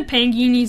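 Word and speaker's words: Pagani: We've seen the Pangini Zonda, said it wrong the last time. It's actually Pangini. we've seen the Pangini Zonda C12F before, but not in Roadster Edition.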